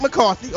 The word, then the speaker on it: McCarthy: Mike McConaughey. Mike Mack. – Yes. – Mike Mack. Listen to pronunciations. Mike McCarthy. – Mike McCarthy. Okay.